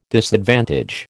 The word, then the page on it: disadvantage: American English